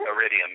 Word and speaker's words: Iridium: Iridium, yeah.